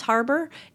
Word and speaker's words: Harbour: I think it's pronounced Harbour, H-A-R-B-O-U-R.